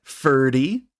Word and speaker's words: Ferdy: We've got Ferdy, and you can remember him because it rhymes with nerdy, and he is the nerdy one of this group.